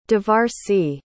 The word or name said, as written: Devarsi